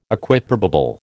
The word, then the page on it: Equiprobable: American English